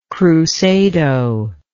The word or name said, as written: crusado